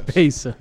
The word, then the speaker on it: Bezos: It's Bezos.